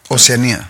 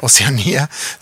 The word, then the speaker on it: Oceanía: Tienes UEFA, obviamente, tienes Oceania, ¿o cómo se dice? – Oceanía. – Oceanía, sí.